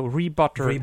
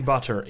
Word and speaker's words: Rbutr: Also, to make sure, it's spelled R-B-U-T-R, so Rebutter. – Rebutter, yeah.